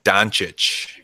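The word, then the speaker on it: Dončić: Can I not say his name right? How do you say it? Doncic? – Doncic, yeah.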